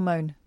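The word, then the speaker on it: Mone: Later, 29-year-old dad of one, Conor, got into a Twitter scrap with fans for posing with Tory peer, and I never know how to say her name, Michelle Mooney. – Michelle Moan.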